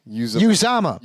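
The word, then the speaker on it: Uzomah: Uzama. Because in the pronunciation, it's Yew dash and then big za.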